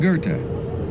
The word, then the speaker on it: Goethe: But what did they do for the Gold Coast Street, some people call Gothi, named after a German poet? – We really just went back to what the generally accepted pronunciation of Johann Goethe's name is. – Goethe.